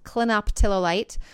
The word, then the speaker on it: clinoptilolite: which come from a zeolite called clinoptilolite.